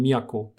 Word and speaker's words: Miyako: Hauptstadt ist dann einfach beispielsweise nicht mehr Kyo, sondern Miyako.